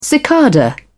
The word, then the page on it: cicada: British English